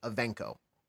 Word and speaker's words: Ivanko: Yeah, Art was saying the A in the middle sounds like an E. So like Ivanko.